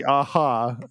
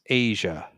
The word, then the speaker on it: Aja: There's a track where they say Aja, but I guess it could also be Aha, if you pronounce the J as a soft H, but, I think in the song is pronounced Asia.